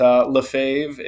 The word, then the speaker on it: Lefevre: I answer to anything, but Lefevre is how I'm told it's supposed to be said.